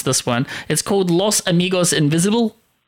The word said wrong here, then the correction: Los Amigos Invisibles: Yes, Los Amigos Invisibles.